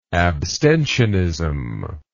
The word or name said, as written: abstentionist